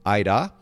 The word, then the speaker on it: Aira: It's spelled A-I-R-A, Ida. Huh. So like with the R is rolled, but it sounds very similar to your name.